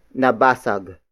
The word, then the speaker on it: nabasag: Baso is spelled as B-A-S-O. It means drinking glass, which is the focus of this sentence. The second sentence begins with nabasag. Nabasag. Nabasag. Nabasag is spelled as N-A-B-A-S-A-G.